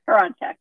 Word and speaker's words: Hroncich: Hroncich.